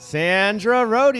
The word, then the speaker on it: Sandra Rhode: Please welcome to the podcast, Sandra Rhode.